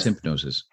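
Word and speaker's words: Hipgnosis: The cover art that this guy Hypnosis did, I don't know if I'm saying his name right. I've never heard it said aloud. – Yep, and it's Hypnosis.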